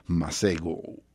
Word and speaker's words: Masego: Masego, como lo se escribe, como lo dije.